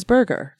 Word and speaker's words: Berger: And I don't know if he was French or German, and it's the German pronunciation. the The English pronunciation is burger.